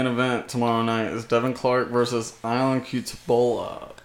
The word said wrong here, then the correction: Cutelaba: Cutelaba. – Cutelaba. I fucking butchered that.